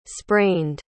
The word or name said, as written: sprained